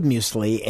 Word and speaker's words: muesli: The Swiss call it M-U-E-S-L-I, muesli.